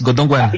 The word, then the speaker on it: Godongwana: Yeah, it's Godongwana.